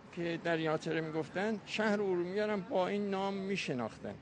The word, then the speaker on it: ارومیه: به عنوان مثال، مثلا یه جغرافیدانی در قرن پنجم میزیسته به نام استخری، این کتابی داره به نام مسالک و ممالک، در اونجا شهر ارومیه رو که خودش دیده به صورت الف ر و میه، یعنی ارومیه به این صورت تلفظ میشه.